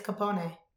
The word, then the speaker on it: Capone: Right? It's Capone.